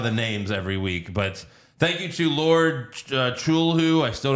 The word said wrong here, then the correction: Cthulhu: Lord Cthulhu. – Cthulhu.